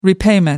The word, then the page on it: repayment: American English